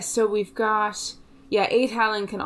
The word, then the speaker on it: Aed Allan: It depends on your Irish pronunciation. – Is that two words? – Two words. A-E-D space A-L-L-A-N. So Allan is the same, but it's along the lines of Aed Allan, I think.